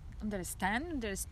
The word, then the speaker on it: understand: In the American English language, the T and the D often disappear. Make sure that the D disappears and you will have an easier time pronouncing it. Understand. Yeah, you don't even need the D.